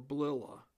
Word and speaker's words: Blila: His name is Andrew Blila, or Blilla. Spelled B-L-I-L-A. I would probably prefer to pronounce it as Blila.